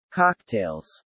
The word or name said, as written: cocktails